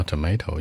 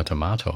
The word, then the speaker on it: tomato: 注意美式发音可以叫 tomato, 英国人叫 tomato 啊，所以区别。